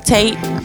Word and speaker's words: Taitt: Am I pronouncing that right, Taitt? – Yes, it's Taitt.